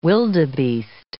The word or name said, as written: wildebeest